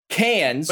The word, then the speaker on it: Cairns: Uh, the cranes ones, the C A I R N S is cans.